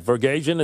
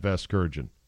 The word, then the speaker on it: Vasgersian: Vergasian, is that how you say it? – No, you're not saying it right.